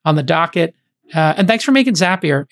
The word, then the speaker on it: Zapier: And I think I was the one who came up with Zapier makes you happier.